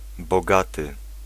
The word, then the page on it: riche: France; [ʁiʃ]